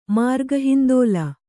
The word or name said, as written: ಮಾರ್ಗಹಿಂದೋಲ